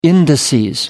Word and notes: indices: American English

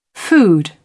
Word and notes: food: British English

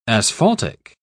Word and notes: asphaltic: British English

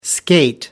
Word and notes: skate: American English

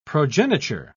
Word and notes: progeniture: British English

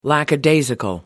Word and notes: lackadaisical: American English

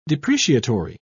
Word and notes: depreciatory: British English

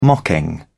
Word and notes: mocking: British English

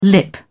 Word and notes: lip: British English

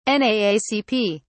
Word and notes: naacp: British English

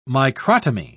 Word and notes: microtomy: British English

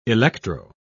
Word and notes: electro: British English

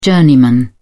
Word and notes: journeyman: British English

